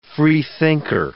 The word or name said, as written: freethinker